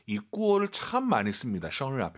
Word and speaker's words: 生日: 정말 많은 분들이 生日, 生日, 르. 한글에 르 있잖아요. 그게 들리면 안 되세요. – 没错. – 生 하시고 입 벌린 상태에서 生日. 혀만 천장에 대면서 드럭 生日.